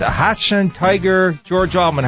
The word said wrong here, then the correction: Hanshin: How do you pronounce that team from Japan? – Hanshin.